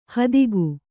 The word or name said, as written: ಹೆತ್ತೇಗ